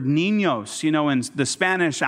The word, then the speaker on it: niños: It's the end with that little squiggly line above it, and that tells you that that N is pronounced with a nya. It has a Y on it, and that's why it says niños, and it's the two types of N.